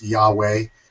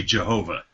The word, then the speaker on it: Yahweh: It's an excellent wordplay on the divine name of Jehovah, which is just simply four letters in Hebrew, YHWH, probably pronounced Yahweh anciently. It's kind of a complicated story how it ended up being Jehovah, but basically it comes from Hebrew and then through Latin and then to English. – The one thing we're sure of is it cannot be Jehovah. – It can't be, yeah. There's no hard J and right.